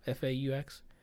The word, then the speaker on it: faux: Like the how do you pronounce that word? Faux? FAUX? – Faux.